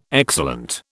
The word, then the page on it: excellent: British English